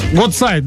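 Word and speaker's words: Гуцайт: Министр молоді та спорту Украины, олимпийский чемпион Вадим Гудсайт. – Доброе утро. – Гудсайт, да, немножко ударение неправильно поставили, окей.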